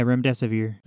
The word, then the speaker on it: remdesivir: This pill is an antiviral, kind of similar to, was it remdesivir? Is that how it's pronounced? – Yeah, remdesivir.